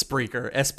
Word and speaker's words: Spreaker: It's Spreaker, Spreaker. – Like speaker, but candy, and then Ker, like yeah, Spreaker.